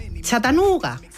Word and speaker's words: Chattanooga: Yo no sé decirlo. Vamos a intentar que nos ayude... Eso que pone siempre en Google de pronuncia, no sé qué. Vamos a escuchar cómo lo dice. – Chattanooga. – A ver, Chattanooga. ¡Uy, qué bien me ha quedado!